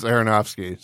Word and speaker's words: Aronofsky: But the people that comes to my mind first is Del Toro, Darren Aronofsky. I probably butchered his name. – Actually, I think you got it right. It's Aronofsky. So you're pretty much there.